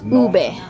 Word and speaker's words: ube: It's ube.